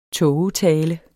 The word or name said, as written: tågetale